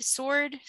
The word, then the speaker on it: sword: Sword? Sword? – Sword. – Sword? – Yeah, sword. – The W is silent.